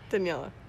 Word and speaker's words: Daniela: You are Daniela. – Why did he say your name like with an accent? – I'm not white. I am very much a Latin person.